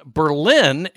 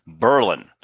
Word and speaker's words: Berlin: In the town in Connecticut I grew up, they pronounce it Berlin. And you will be corrected instantly if you call it Berlin. So it is Berlin, Connecticut.